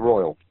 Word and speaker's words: Royal: Royal. – Yeah. – Which I pronounced Royale a moment ago. – Like it was a burger.